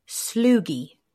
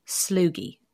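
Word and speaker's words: Sloughi: Is a Sloogie. Sloogie. – Mm, that sounds very respiratory. – Oh, yeah, it could be. Rhymes with loogie.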